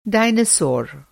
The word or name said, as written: डायनासोर